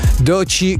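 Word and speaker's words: Doechii: Francesco e vari amici che ci ascoltano, che sono amanti della musica anche urban, sicuramente conoscono Doci, che io pronuncio così, ma si scrive Doechi con due finali.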